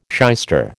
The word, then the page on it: shyster: American English